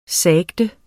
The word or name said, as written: sagte